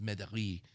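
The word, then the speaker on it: Mederies: It's M-E-D-E-R-I-E-S. Wow. It's a French name. I think it's pronounced Medari.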